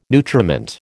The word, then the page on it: nutriment: American English